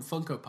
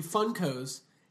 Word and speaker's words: Funko: Conan was like, oh, these are your new Funkos. And he was like, you're saying it wrong, Conan. It's Funko. It's Funko, not Funko. It's not a Funko. It's Funko. Funko Pop.